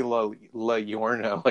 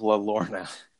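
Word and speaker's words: Llorona: I've seen, or would it be La Yorna, like tortilla? – Tortilla. – Brenda Brent, like a tortilla. Brenda Brent texts us about this one. – About the movie? – Pronunciation of La Lorna. Because I think it's double L's.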